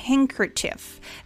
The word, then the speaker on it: handkerchief: Ella dice handkerchief. De hecho, aquí en la transcripción no existe ninguna D. Entonces, esta de aquí se le llama silent letter.